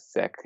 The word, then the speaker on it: Sikh: The proper pronunciation is Sikh. It's not like, quote unquote, offensive. It's just, it's more accurate to say Sikh rather than Sikh.